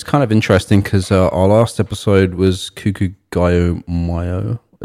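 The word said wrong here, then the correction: Kikagaku Moyo: Am I saying that correctly? – No. – Kikugaku Mayo. – Yeah.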